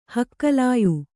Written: ಹಕ್ಕಲಾಯು

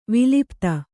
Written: ವಿಲುಪ್ತಿ

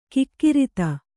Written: ಕುಕೃತ

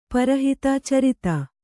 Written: ಛುರಿತ

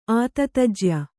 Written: ಆತತಜ್ಯ

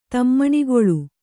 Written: ತಮ್ಮಣಿಗೊಳ್ಳು

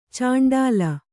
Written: ಚುಂಡಿಲಿ

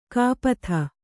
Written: ಕಪಿತೆ

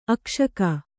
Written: ಅಕ್ಷಕ

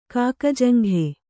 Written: ಕಾಕಜಂಘೆ

